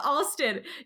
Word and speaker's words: Alston: Okay, we are back and we are so excited to have Lieutenant Colonel Harold Ashton here with us. Lieutenant Colonel. Okay, that's quite the title. – Let me correct you one thing. We pronounce it Alston. – Oh, Alston.